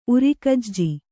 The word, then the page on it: ಉರಗೆಜ್ಜೆ: uragejje